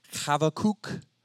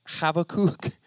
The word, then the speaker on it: Habakkuk: The most scholarly scholars say it's probably something like Habakkuk. Habakkuk. So we're going to go with Habakkuk.